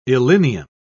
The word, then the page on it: illinium: British English